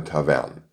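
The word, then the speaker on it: Tawern: Aus dem lateinischen Wort Tabernae bildete sich im Laufe der Zeit der Ortsname Tavern, wobei entgegen der sonst üblichen Aussprache bei ähnlichen Ortsnamen im Deutschen die Betonung auf der zweiten Silbe des Namens liegt und so auch heute noch auf den römischen Ursprung unseres Ortes hinweist. Von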